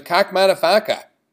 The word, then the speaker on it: Kakkmaddafakka: We finished up that opening set of the 10 o'clock hour with Kakmadafaka. That is spelled K-A-K-K-M-A-D-D-A-F-A-K-K-A.